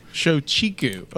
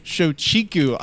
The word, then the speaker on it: Shochiku: How would you pronounce it? – Shochiku. I think that's Shochiku. – Shochiku. Okay. So... Shochiku, I believe how you pronounce it.